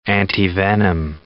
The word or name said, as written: antivenom